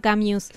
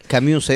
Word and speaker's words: Camus: Sí, siempre me quedó esa dura Albert Camus. – Camus es.